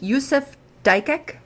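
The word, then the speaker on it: Yusuf Dikeç: Yusuf Dikic.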